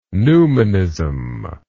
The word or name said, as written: newmanism